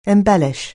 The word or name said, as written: embellish